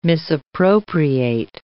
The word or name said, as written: misappropriate